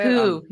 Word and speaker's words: Koo: Is that how you say Koo. – It? – Koo. Yeah, Koo. Not Yo.